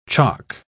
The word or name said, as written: chock